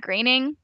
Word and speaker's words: Groening: But it just was not really my jam, although I feel like that kind of makes sense because I know that it's a Matt Groening. That's how you say it, right? – Yeah.